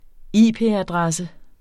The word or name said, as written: IP-adresse